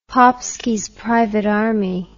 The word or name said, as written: Popski's Private Army